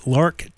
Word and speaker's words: LARC: what is it called, the LARC tax? Is that the right way to say that? That's the correct.